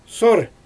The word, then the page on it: sor: Xyzyl